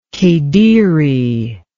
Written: kediri

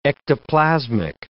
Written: ectoplasmic

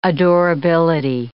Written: adorability

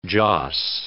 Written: joss

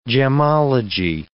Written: gemology

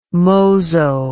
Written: mozo

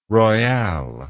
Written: royale

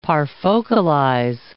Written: parfocalize

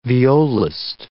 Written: violist

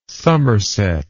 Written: summerset

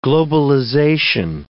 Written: globalization

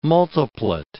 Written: multiplet

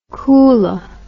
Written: koolah

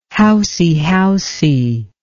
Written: housey-housey

